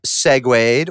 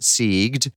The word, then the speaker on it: segued: Okay, so obviously we've segued, or as someone close to me used to mispronounce it, sieged.